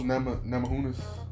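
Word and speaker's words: Namajunas: Rose Namahunas? Namahunas? – Sounds right.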